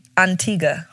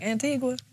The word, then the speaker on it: Antigua: Did I say that right? Antigua or Antigua? – When you're there, they say Antigua. – Antigua. – But, you know, the flight attendants be like, Antigua.